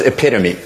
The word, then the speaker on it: epitome: This word for a perfect example doesn't rhyme with home. – What is epitome? – Yes.